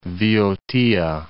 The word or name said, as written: Voiotia